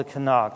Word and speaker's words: Tanakh: It's called the Tanakh.